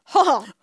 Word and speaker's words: Hohl: Sorry, how do you say that? – H-O-H-L. – Oh, Hall. – Hall. – Oh, that's easy.